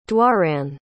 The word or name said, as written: doireann